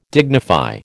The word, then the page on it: dignify: American English